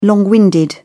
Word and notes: long winded: British English

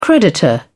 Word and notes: creditor: British English

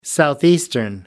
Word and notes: southeastern: American English

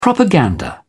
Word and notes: propaganda: British English

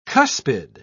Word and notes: cuspid: British English

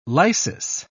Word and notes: lysis: British English

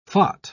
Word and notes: phot: British English

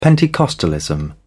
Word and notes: Pentecostalism: British English

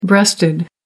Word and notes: breasted: American English

